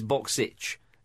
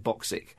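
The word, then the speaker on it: Bokšić: Really irritated by some commentator years ago when Alan Boksic was playing for Juventus, and it's Boksic. It's in English, it's spelled Boksic. Yeah. Of course, he pronounced it Boksic.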